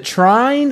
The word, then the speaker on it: Trine: Is it Trine? Is that how you pronounce that game? – Yeah.